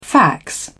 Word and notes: fax: British English

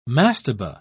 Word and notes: mastaba: British English

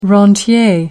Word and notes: rentier: American English

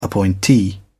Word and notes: appointee: British English

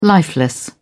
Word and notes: lifeless: British English

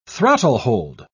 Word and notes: throttlehold: British English

